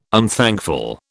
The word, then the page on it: unthankful: British English